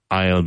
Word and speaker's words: I'll: I'll be careful. I apostrophe LL, pronounced I'll. The same pronunciation as A-I-S-L-E, which is the central passage from the door of a church to the altar. I'll, A-I-S-L-E, I'll.